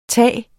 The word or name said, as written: tage